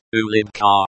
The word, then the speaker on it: улыбка: Pronounced улыбка in Russian.